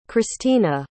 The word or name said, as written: khrystyna